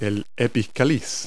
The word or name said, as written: epicalyx